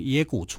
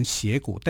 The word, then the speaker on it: 斜谷: 啊，有两个军在抵抗他，他的主力部队呢是在打陇右，那疑兵部队是赵云率领了，从野谷出发来当成疑兵。这个野谷啊，我们写成斜啊，也可以念成斜谷，但古音叫做野啊，所以我们把它念成叫野谷。